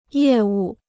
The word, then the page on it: 业务: yè wù